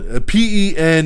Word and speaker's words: Pentaverate: We have the first teaser trailer for Mike Myers' upcoming Netflix comedy event, The Pentaverte. Whatever the fuck it's called. Pentaverate.